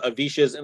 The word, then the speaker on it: Avish: There's not a lot of Avishes in the world, so if you type Avish, A-V-I-S-H, into Google, I'll be like one of the first couple of results.